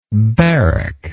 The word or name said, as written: barracker